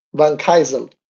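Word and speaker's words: Vancaeyezeele: Thomas Van Key, es que lo digo mal, Van Kaisel, de Guyana francesa.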